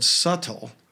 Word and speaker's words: subtle: And we think the word subtle. – Yes. – Which you don't pronounce the B, Mm-mm.